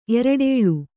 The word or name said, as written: ಎರಡೇಳು